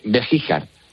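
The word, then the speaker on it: Begíjar: Beguijar, a 6 kilómetros de Baeza. – Perdona, Bejíjar. – Bejíjar, o sea, es las dos con J, ¿no? – Complicado. Bejíjar, GJ, pero se pronuncia Bejíjar, vale.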